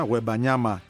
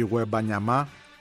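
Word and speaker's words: Wembanyama: Παρεπιπτόντως, το μεγάλο ταλέντο, επειδή τον διαβάζω με διάφορους τρόπους, του παγκόσμιου μπάσκετ, ο Βίκτορ Γουεμπανιάμα, προφέρεται στην παραλίγουσα, Γουεμπανιάμα και όχι Γουεμπανιάμα, δεν πάνε να είναι Γάλλος, πολλοί Γάλλοι προφέρονται στην παραλίγουσα.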